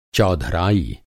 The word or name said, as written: चौधराई